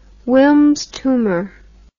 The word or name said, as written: Wilms tumor